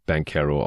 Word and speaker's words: Banchero: Er spricht sich selbst Paolo Banquero aus und deswegen versuche ich es auch so zu machen. Ich weiß, dass äh, Italiener wahrscheinlich normalerweise Banquero sagen würden, aber ich nehmen da normalerweise die Aussprache, die die Spieler selbst mögen.